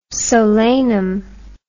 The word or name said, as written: solanum